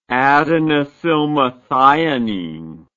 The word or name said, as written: adenosylmethionine